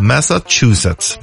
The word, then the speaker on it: Massachusetts: Många har ju svårt att uttala det här. The State of Massachusetts. Det är lite T innan C där. The State of Massachusetts.